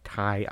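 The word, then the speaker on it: Kei: So in 1943, you know, deep during World War II, the Japanese Navy was occupying the, I believe this is pronounced Kai Islands in Indonesia. It might be K. It's K E I in English.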